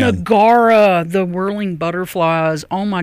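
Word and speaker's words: Gaura: Gara. Rhymes with Laura, but you've got to have you some Gara, some whirling butterflies.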